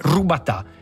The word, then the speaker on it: rubatà: La forma più antica e tradizionale del grissino è il robatà, che bisogna pronunciare come rubatà, che significa rotolato, lungo dai 40 agli 80 centimetri.